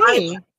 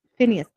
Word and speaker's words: Phineas: Is that a common thing for I think they pronounce it like in the cartoons, it's pronounced Phineas. – Phineas, okay. – Yeah, but he pronounced it Finus.